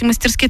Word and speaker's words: мастерски: Кстати, мастерски это правильно. Если кто-то сейчас сказал, что она дура несет, мастерски это правильно. Ударение ставится именно на мастерски.